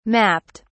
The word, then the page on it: mapped: British English